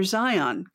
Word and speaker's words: Zion: Maybe a little less emphasis on the second half of the word. I think Zion is how most people will say it.